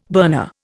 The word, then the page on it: burner: Indian